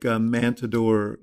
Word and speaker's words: Mantador: Limbaugh did, and obviously Trump has, in places like Matador, North Dakota, where you grew up.